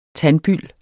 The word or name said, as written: tandbyld